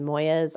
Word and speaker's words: Moyes: Moyes.